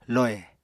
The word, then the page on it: lohè: Apatani